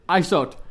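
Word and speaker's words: Isaute: I'm kind of winded after all that trampolining, but it's a great time here back at ISOT, right? – ISOT is, yeah. – It's I-S-A-U-T-E, right? – You got it. – Okay, so if you don't have a French accent, that is how you pronounce it.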